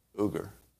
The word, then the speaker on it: Uygur: Cenk Uygur or Uyghur? Uyghur. Cenk Uygur.